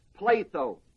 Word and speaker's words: pletho: Now that word is the word "pletho," spelled filled, P-L-E-T-H-O, long O.